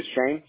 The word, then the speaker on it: Chane: Just one thing, your first name, you do spell it Alexander, and the last name you do spell it C-S in Charlie, H-A-N-E. Is that correct? – So, no, sir. My first name is Chain, C-H-A-N-E.